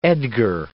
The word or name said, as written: Edgar